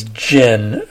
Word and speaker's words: djinn: You know, in other cultures, in uh, the Islamic culture, for instance, long long ago, centuries ago, um, the word is jinn, uh, not like Tanqueray Gin, but it's spelled in the Romanized alphabet, D J I N N, and the anglicized word is genie, like the genie comes out of the bottle. So the in the Islamic culture,